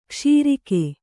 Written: ಕ್ಷೀರಕ

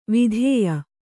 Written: ವಿಧೇಯ

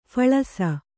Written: ಫಲಾಸಿ

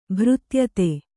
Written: ಭೃತ್ಯತೆ

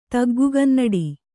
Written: ತಗ್ಗುಗನ್ನಡಿ

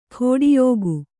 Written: ಖೋಡಿಯೋಗು

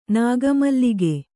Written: ನಾಗಮಲ್ಲಿಗೆ

